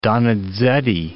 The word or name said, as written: Donizetti